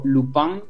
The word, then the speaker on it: Lupin: Es la serie Lupin, o Lupin, si lo queremos decir con una pronunciación más francesa.